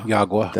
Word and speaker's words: Yagua: Yagua? – Yagua. – That is the correct pronunciation.